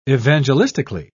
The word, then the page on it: evangelistically: British English